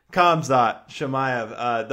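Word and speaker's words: Khamzat Chimaev: Between him and Kazmat, how the fuck you say his name? – Kamzat Chimaev.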